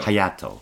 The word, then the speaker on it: Hayato: Hayato.